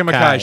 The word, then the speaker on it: Makkai: Makkai. Rebecca, Rebecca Makkai.